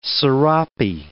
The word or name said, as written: sarape